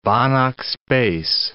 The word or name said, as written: Banach space